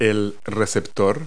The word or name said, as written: receiver